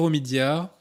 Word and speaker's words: Omidyar: Et enfin, euh, nous avons Monsieur Pierre Omidyar. Est-ce que je prononce bien Est-ce que je prononce bien C'est ça. Pierre Omidyar, qui est lui fondateur de eBay.